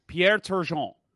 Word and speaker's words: Pierre Turgeon: Pierre Turgeon.